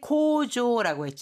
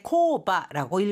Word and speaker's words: 工場: 자, 공장이라고 할때 고조라고 했잖아요. 이건 또 다른 읽는 방법이 있는데 코바라고 읽을 수도 있습니다. 자, 고조라고 읽을지 코바라고 읽을지, 코바로 읽었을 때는 규모가 아주 작은 공장이 돼요. 그러니까 간의 수공업이라든가 아주 작은 규모일 때는 코바라고 읽고요. 일반적인 어느 정도의 규모를 갖춘 공장을 말할 때는 고조와 같이 읽어줍니다.